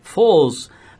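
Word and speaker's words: Foals: A gente separou aqui então a banda inglesa Falls, é Falls que fala? – Yeah. É. é, né? – Falls.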